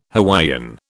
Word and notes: Hawaiian: British English

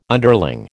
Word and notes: underling: American English

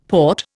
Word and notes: Porte: Indian

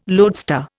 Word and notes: loadstar: Indian

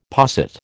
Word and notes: posset: American English

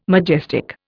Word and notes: majestic: Indian

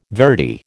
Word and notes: verity: American English

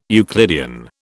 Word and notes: Euclidean: British English

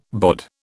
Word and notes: BOD: British English

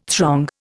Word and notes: throng: Indian

